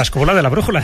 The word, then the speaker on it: Escóbula de la Brújula: Director de la Escóbula de la Brújula. ¿Cómo es eso? ¿Lo he dicho bien? – Sí, perfecto, la Escóbula de la Brújula.